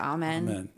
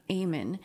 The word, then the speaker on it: Amen: His name is, it looks like it says Amen, but his name is pronounced Amen.